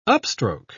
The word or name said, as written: upstroke